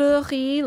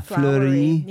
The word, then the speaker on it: Flori: Flori, like French, like Flori. – Yeah, like flowers.